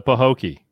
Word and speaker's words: Pahokee: Quad is a 23-year-old from, now, am I saying this right, Mr. Floridian? Pahokee? P-A-H-O-K-E-E. Pahokee, Florida? – Sounds good to me.